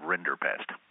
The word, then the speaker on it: Rinderpest: Everybody's heard of smallpox, and nobody's ever heard of Rinderpest.